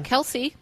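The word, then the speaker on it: Kelsea: And it's got someone on the cover named Kelsey or Kelsia, however you say her name. – Kelsey.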